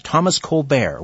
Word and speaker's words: Colbert: And I might mention to you, I pronounce it the French-Canadian Colbert. – Way, Steve Colbert. – All right. Thank you for that. Thomas Colbert.